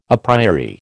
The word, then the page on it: a priori: American English